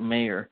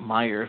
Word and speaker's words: Mayer: but it was still spelt Mayer. So I was like, how do you pronounce that wrong for all these years? Because it's Mayer, not Meyer, if it's an A.